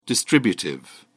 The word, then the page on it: distributive: British English